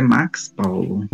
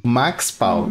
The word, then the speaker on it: Max Paulo: Então, o meu nome, na verdade, é Max Paulo. Max Paulo.